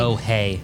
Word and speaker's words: Bohai: It's Bohai.